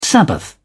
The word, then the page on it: sabbath: British English